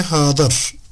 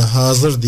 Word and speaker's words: hazır: Biz Arap dilinde bu ifadeyi, bu kipi nehy-i hadır veya Arapçadaki dat harfinin dilimizde z sesiyle karşılanması bir adet olduğundan, bir teamül haline geldiğinden hadır değil de hazır diye okumak artık yurdumuzda çok yaygınlık kazandığından biz de böyle telaffuz ediyoruz.